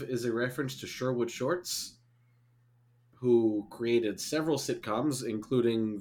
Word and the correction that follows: Schwartz: Yeah, he's, unless I'm saying his name wrong. Yeah, Sherwood Schwartz is his name.